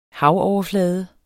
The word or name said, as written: havoverflade